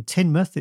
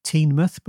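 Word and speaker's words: Teignmouth: So it's one of those odd things where the Teen, which is spelt T-E-I-G-N, that's the name of the river, is pronounced differently depending where it is. So in Tynmouth, it's pronounced just like tin, even though you'd think it'd be Teenmouth, but it's not, it's Tynmouth.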